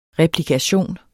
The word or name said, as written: replikation